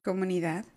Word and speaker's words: comunidad: Comunidad, right? – Comunidad. – That's how you say it? Mm-hmm.